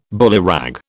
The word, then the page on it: bullyrag: British English